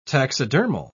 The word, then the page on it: taxidermal: British English